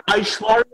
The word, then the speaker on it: Aishwarya: Arishwara. – You want to get Aishwarya Rai to play the lead? – Yes.